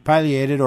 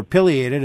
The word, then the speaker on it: pileated: Yeah, actually, you can pronounce it either way. Either way is correct, pileated or pileated.